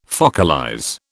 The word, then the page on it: focalize: British English